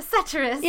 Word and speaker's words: satirist: Satirist? How do you say that? – A satirist. Yeah, he wrote satire.